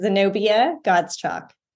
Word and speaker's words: Zenobia Godschalk: This is Zenobia Godschalk.